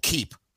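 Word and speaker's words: Keap: In full disclosure, I partner with a company called Keep, who has a CRM and all-in-one kind of Swiss Army knife tool like that.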